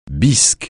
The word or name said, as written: bisque